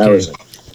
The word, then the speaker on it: Arizin: Am I saying it right? It's Arizin, right? – Yes. – Okay. Arizin. Charles Barkley.